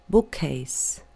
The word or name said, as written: bookcase